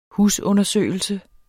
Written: husundersøgelse

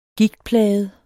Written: gigtplaget

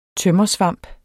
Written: tømmersvamp

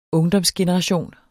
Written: ungdomsgeneration

